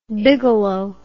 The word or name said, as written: bigelow